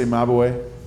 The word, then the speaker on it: Mabuhay: The Mabuhay Gardens was a restaurant. – Am I saying it wrong when I say Mabuhay? – Mabuhay. Which means long live.